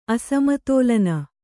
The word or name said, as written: ಅಸಮತೋಲನ